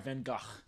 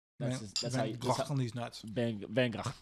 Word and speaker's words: Van Gogh: That's very clearly Vincent Van Gogh. – Okay, Vincent Van Gogh anamorphs into a cat. – Sorry, Van Gogh. – Van Gogh. – That's how you say his name.